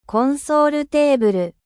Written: console

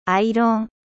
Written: fer a repasser

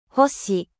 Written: etoile

